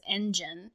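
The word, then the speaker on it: Engene: And then I think their fandom name is Engine. But it's not spelled engine. It's spelled and gene. So like the genes, like the genetics of DNA will like, because they're all brought together kind of thing.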